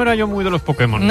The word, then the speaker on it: Pokémon: Para permitir que Putemon. – El poder que hay en Pokémon.